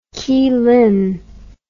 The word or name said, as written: kylin